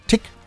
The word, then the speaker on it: Tich: Dafty Dozy Breaky Mick and Tick Tick Tick. Hideaway, Hideaway. Ich muss immer aufpassen, dass ich das ordentlich ausspreche. Ne? T-I-C-H wird Tick ausgesprochen. Ja, so wie Tick-Tack, Tick-Tock, Tack, Tick-Tack, Tick.